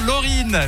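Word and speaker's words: Loreen: Félicitations, Laurine. Effectivement, c'est comme ça qu'on le prononce, la chanteuse suédoise, double vainqueur de l'Eurovision.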